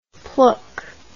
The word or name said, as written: plook